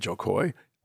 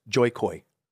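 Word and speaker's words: Jo Koy: You met Joe Coy. – I love that you said it right. – Yes. I've been practicing, actually. – You know what he usually says? Joy Coy.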